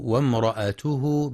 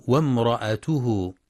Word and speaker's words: وامرأته: وفي قوله جل في علاه وامرأته حمالة الحطب، يخطئ من يقرأ وامرأته بهمزة قطع مكسورة بعد الواو وامرأته، والصواب أنها همزة وصل تسقط وصلا فتنطق وامرأته.